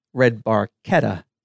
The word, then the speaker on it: Barchetta: If you're going to pronounce YYZ, then you also have to pronounce Red Barchetta, Red Barchetta. – Well, because that's how it's pronounced in Italian.